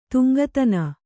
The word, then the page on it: ತುಂಗತನ: tuŋgatana